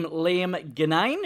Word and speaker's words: Liam Ginnane: Oh, you actually got it right. – Yeah. – You know, no one gets it right. Everyone's like Ginnane or Ginnane or so. They just like come up with some other pronunciation of it. You nailed it.